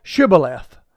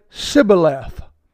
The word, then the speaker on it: Shibboleth: Then said they unto him, Say now Shibboleth. And he said, Sibboleth. For he could not frame to pronounce it right.